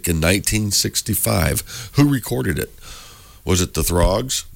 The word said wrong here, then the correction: Troggs: Am I saying that right or not? – Troggs. – Troggs. – Like troglodyte.